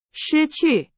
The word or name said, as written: 失去